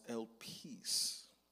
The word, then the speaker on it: elpis: So the word hope in the Greek is pronounced elpis, and it is the expectation of good.